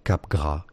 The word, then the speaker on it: Capgras: Capgras is how it is said in French. In French, the S at the end is silent. Now, in English, it's generally referred to as Capgras syndrome. But if you want to be, you know, a little bit better, Capgras, it would what?